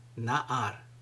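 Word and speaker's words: na'ar (נער): In Hebrew, this word is pronounced na'ar.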